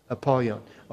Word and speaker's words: Apollyon: But in the Greek, his tongue is Apollyon. Can you say his name? Apollyon.